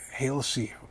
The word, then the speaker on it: Halsey: Hazley? Is that how you say it? Halsey.